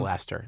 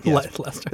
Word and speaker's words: Leicester: It's actually pronounced Leicester. It's pronounced Leicester. – It's pronounced Leicester. – I've never heard anybody say that word. – Oh, well, it's pronounced Leicester. Now you know.